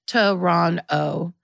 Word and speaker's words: Toronto: Toronto, pronounced Toronto, or in traditional Mohawk, Tuckaronto.